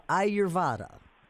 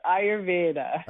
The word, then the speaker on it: Ayurveda: We're talking to a neurologist, a neuroscientist, and internationally recognized as an expert in the ancient practice of Ayurveda. Did I say that one right? – You came very close. It's Ayurveda.